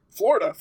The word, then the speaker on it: Flo Rida: Florida? – Florida is his boss. – Mr. Florida. – No, it's Flo Rida. I would actually, come on, pronounce it right. – Oh, fuck. – Flo Rida and Florida.